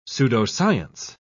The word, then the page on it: pseudoscience: British English